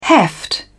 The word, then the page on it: heft: British English